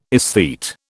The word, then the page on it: esthete: British English